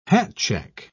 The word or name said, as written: hatcheck